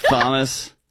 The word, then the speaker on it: Thomas: Thomas Sowell. Thomas. – Wait, it's actually Thomas. – Stop. – It's Thomas. Sowell.